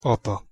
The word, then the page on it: apa: Hungarian